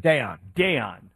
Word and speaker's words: Daiyan: So that guy spells his name D-A-I-Y-A-N. Do you know how to pronounce it? – Dayon.